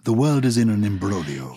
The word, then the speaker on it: imbroglio: It's spelled imbroglio, isn't it? – But is that not correct? – It's a complicated situation. Imbroglio, it's Italian. Imbroglio. – Imbroglio. – Yes. – I-M-B-R-O-G-L-I-O.